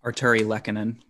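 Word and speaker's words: Artturi Lehkonen: Arturi Lehkonen.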